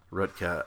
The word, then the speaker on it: Roethke: There's a great poem that I want to read from Theodore Rutke, which is spelled R-O-E-T-H-K-E, but it's pronounced Rutke, I think.